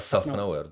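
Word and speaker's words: Szafnauer: a vlastně mě strašně překvapuje, jak eh, špatný eh, Otmar Šaf, já to neumím. – Otmar myslet. Safnauer, on si Safnauer. Takhle, a Safnauer. Přímo takhle Safnauer. sám to vyslovuje, člověče, taky mě to překvapilo.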